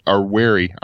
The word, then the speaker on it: Wary: So Puppy Linux, the Juarez version of Puppy, Wary version of Puppy ran great on that. – Juarez is a whole different thing. – How do you spell that? – I'm sorry? – How do you spell that? – W-A-R-Y. – W-A-R-Y, Wary Linux. – Just like the word, okay.